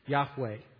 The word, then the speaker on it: Yahweh: capital L-O-R-D. This is put in place of the Hebrew tetragrammaton Yod Heh Vav Heh, or Y-H-W-H, often pronounced Yahweh.